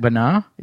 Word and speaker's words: Bana: It's two syllables. – I thought it was Eric Bana. – Bana or Bana, I've heard of. – I think John Burr is the only person I've talked to that pronounces it Bana. – Eric Bana. That's not correct? – No. – That if it was Bana, there would be an accent, and it would be over the second A.